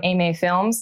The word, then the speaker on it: AMA Films: So at Cascadia 2017, I was thrilled to see multiple submissions come in from AMA Films. That's how you say it, right? – Yeah. – Okay, good. – You got it perfect.